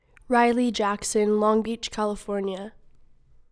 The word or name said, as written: Riley Jackson